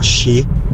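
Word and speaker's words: shi (四): Sì, il numero 4 in Giappone è considerato che porta sfiga perché si pronuncia in, anche in altri modi, ma tra i modi anche, si pronuncia shi.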